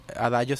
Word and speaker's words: Adagio: O buscar simplemente Adayo, es más fácil, ¿verdad? Adayo se escribe Adagio.